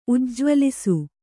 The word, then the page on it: ಉಜ್ಜ್ವಲಿಸು: kannada